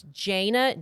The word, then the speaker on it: Jayna: I say sometimes say Jana, but it's Jaina, J-A-Y-N-A.